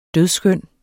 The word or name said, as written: dødskøn